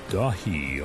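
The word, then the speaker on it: Daithí: A new album from Elyon called Dahi, although it's spelled D-A-I-T-H-I. I'm pretty sure it's in Gaelic.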